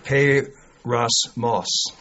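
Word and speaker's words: peirasmos (πειρασμός): But it's pronounced peirasmos. Sounds like a kind of moss that grows on trees or but it's not.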